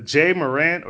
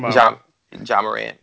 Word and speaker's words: Ja Morant: Actually, can I ask you, and I don't even know if I'm going to say this right, but Jay Morant or Ja Morant from... – Ja Morant.